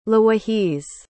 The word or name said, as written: lawahiz